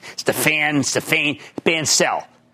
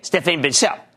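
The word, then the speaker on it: Stéphane Bancel: And I bumped into this real nice guy, Stephanie, I wasn't sure how to pronounce his name, Stephane Bancel, okay? Stephane Bancel.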